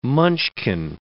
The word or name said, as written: munchkin